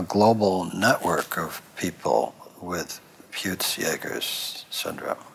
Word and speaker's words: Peutz Jeghers: So, one of your many adventures that I don't want to ignore is uh, that with this lifelong experience with, do you say Peutz Jeghers? Is that how you say it? – Peutz Jeghers, yeah.